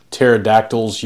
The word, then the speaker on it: pterodactyls: Why can you not hear pterodactyls use the restroom? – Oh, I know. I already know. – Because the P is silent.